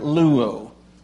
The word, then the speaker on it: luo: The word free is L-U-O. The word to watch is L-O-U-O. And they're pronounced exactly the same.